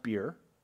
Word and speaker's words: bier: Yes, it's pronounced beer.